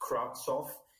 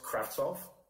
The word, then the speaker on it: Kravtsov: It's not Kravtsov. Well, it's pronounced Kravtsov. And when I hear people say Kravtsov, that just annoys me. – It's not a hard T. There's no T. – Well, there is, but the problem is people pronounce the S before the T.